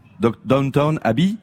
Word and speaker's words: Downton Abbey: Downton Abbey. – Downton Abbey. – Abbey, c'est ça. Hein, on est d'accord sur la prononciation.